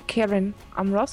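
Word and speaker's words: Cerin Amroth: This is Karen Amroth, so everything that starts with a C. In Elvish, you pronounce with a K, as a K.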